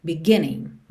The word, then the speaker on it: beginning: Aqui, por conta da música, a gente tem beginning, mas normalmente essa palavra teria a sílaba tônica na segunda sílaba, ok? Beginning, beginning.